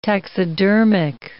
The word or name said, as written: taxidermic